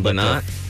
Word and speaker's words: Lynott: Lynott.